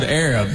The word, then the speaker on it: Arab: It's spelled A-R-A-B. They don't pronounce it Arab. They pronounce it Arab because the original name was Arad, but they misspelled it on the water tower.